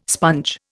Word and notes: sponge: Indian

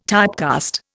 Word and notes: typecast: Indian

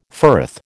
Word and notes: firth: American English

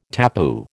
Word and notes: tapu: American English

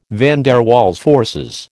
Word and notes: van der Waals forces: American English

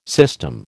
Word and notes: system: American English

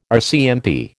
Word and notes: RCMP: American English

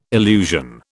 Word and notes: illusion: British English

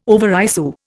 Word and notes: overissue: Indian

